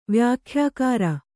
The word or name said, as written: ವ್ಯಾಖ್ಯಾಕಾರ